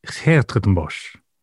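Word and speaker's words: 's-Hertogenbosch: I'm a little bit Dutch, actually, so I can perhaps give it a better... – Go on, off you go. – Sertenbosch. – No, it isn't. – Basically, if you want to do a Dutch accent, you just go... – What about the S at the beginning? – Yeah, it's sort of silent.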